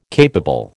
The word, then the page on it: capable: American English